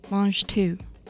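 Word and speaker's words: mangetout: It's pronounced mange tout, which is a French word meaning eating all of the peas and the pod.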